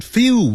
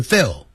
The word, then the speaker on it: feel: If we still don't feel well，如果我们仍然没有感到好的话， feel 不要念成 feel，要念长元音 Feel.